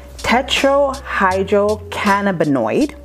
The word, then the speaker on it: tetrahydrocannabinoid: All right, so the THC stands for tetrahydrocannabinoid. And I got that right, right? – That was good.